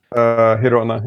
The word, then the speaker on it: Girona: Girona, Spain? – Girona, yeah.